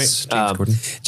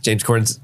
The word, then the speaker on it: James Corden: Am I saying his name right? – James Corden. – James Corden's, she's like the other guest that's on that episode.